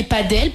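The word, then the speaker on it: padel: C'est juste que c'est pas du tout la même prononciation. – Est-ce qu'on peut juste dire paddle? – C'est paddle pour le tennis. – Et paddle. – Et paddle pour la planche.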